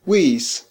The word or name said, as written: wi's